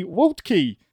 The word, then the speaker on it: Waltke: We'll pronounce his last name correctly, Waltke. – That's absolutely correct. Yes. That's the American version of a Prussian last name that has way too many consonants in it.